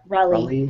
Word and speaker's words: Raleigh: Did I pronounce that right? Raleigh. – Yep, Raleigh.